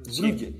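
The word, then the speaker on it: Brugge: Μπριζ δεν υπάρχει μπριζ. Είναι μπρούγκι. Ναι, ναι, ναι. Βρούγκε.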